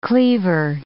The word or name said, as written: cleaver